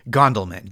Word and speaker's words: Gondelman: It's at Josh Gondelman, G-O-N-D-E-L-man, as Method Man would spell it. – At Josh Gondelman, your name, J-O-S-H-G-O-N-D-E-L-M-A-N.